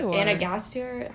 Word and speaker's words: Gasteyer: Anna Gasteyer, is that how you pronounce it? – Yes, she is great as well.